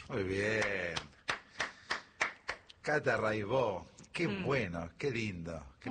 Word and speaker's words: Raibaut: Buenísimo, Cata Raibó. Se escribe Raibaut, métanse, búsquenla, Rai con Y, Baut con B larga. Catalina Raibaut, Raibó se dice.